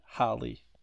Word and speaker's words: Halle: Aliás, cada vez que eu falo o nome da Hale, Halle, Hale, eu falo de um nome diferente, de um nome diferente, de uma maneira diferente. Então, rapidinho, deixa eu ver aqui como se pronuncia. Tá, gente, é Chloe and Halle.